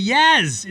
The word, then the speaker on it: Jazz: All right, with that in mind, the games, Utah, the Jazz, it's a soft J, beat the New Orleans Pelicans.